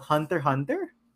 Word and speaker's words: Hunter x Hunter: Oh, so it's not called Hunter x Hunter. It's called Hunter Hunter. – Yeah, apparently that's how you pronounce it.